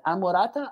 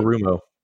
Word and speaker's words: Anarumo: And I will say, I never can pronounce his name correctly, but Anurata, the defensive coach that we have here, defensive coordinator. – Anarumo.